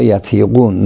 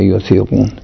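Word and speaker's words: يطيقون: گفته شده صحیح تر شاید این باشه مالا یتیقون نه یوتیقون.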